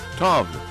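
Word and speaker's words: Tove: I get home, I got the munchies, binge on all my Twinkies, throw ese nombre de ella se pronunciará Toby. Tov. Ok, se escribe T-O-V-E, V pequeña E, Tov Low, L-O, es el nombre artístico, el nombre real de ella es Tov Eva Elsa Nilsson, de Suecia.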